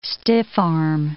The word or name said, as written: stiff-arm